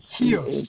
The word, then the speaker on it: Chios: And with the lineage is tracing back, and I don't know if I'm pronouncing this correctly, to the island of Chios. – Chios.